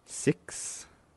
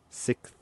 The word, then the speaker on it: sixth: Sixth or sixth? Sixth. Six. Leaving out the X sound. – Or so six. – Or Sith, like Sith Lord from Star Wars.